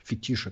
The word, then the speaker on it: фетиш: у меня есть фетиш, фетиш это правильно говорить, собирать футболки с максимально тупыми надписями.